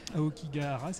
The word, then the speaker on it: Aokigahara: Alors Ningyo, ça raconte l'histoire de Kai, c'est un, enfant, un adolescent qui part dans la forêt de, je vais essayer de pas l'écorcher, Aokigahara, c'est ça Parfait.